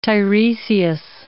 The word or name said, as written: Tiresias